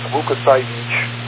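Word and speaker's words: Łukasiewicz: Reverse Polish notation, what's called reverse Polish, is pronounced Lukasiewicz. When you see it spelled out, it looks like Lukasiewicz, but it's pronounced in Poland by Lukasiewicz as a family name.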